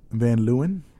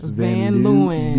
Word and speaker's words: Van Leeuwen: Van Leeuwen. Got to say it phonetically so people know what it is. – Van Leeuwen.